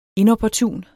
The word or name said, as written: inopportun